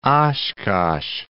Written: Oshkosh

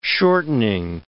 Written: shortening

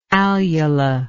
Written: alular

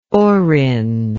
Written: Orrin

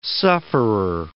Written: sufferer

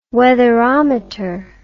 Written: weatherometer

